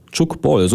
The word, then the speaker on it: Tchoukball: Tchoukball, so muss man es aussprechen, denn die Sportart kommt aus der französischsprachigen Schweiz aus den 70er Jahren.